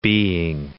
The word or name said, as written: being